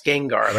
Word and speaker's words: Gengar: Yeah, that's how it's pronounced, Gengar.